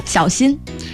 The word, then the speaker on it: 小心: How do you say be careful? – 小心。 – 小心。